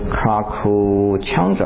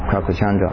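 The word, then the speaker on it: Krakucchanda: 这一尊佛叫做拘留孙佛啊，拘留孙这个字呢，我也把这个梵文的写给大家看啊，是念成 Krakuchandra 啊，应该是这个念法 Krakuchandra。所以你看这个梵的音呢，跟原的字的这音呢差很多，有时候对不上去啊。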